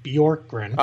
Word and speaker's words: Bjorkgren: It's Nate Bjorkgren.